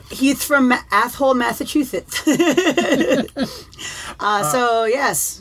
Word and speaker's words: Athol: Today our five answers come from James Maxwell from Athol, Massachusetts. Here with my five answers. That is not pronounced asshole, but it's close, he says. So I guess it's Athol. – Athol.